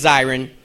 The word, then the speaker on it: Zirin: I got to tell you, first, my my last name is pronounced Zirin, not Zirin. – It's like a, the same thing. – Thank you so much. I appreciate that. It's pronounced Zirin. It's my brother, ladies and gentlemen. – It's spelled Z-I-R-I-N.